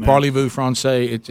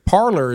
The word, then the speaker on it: parlez: Parlez-vous français. It's not parlez-vous français. You know, and that is their word for to speak, but parlor is not how it's said.